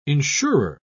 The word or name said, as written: insurer